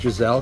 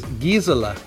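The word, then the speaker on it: Gisele: Now, you know I love you guys, but Stephanie, when you corrected Mark about his pronunciation, actually, it's not Giselle, although I'm sure she would like us to think it's Giselle because that sounds very French and charmant and non-threatening. Her name actually is Gisela, and that is from the German where you pronounce every letter.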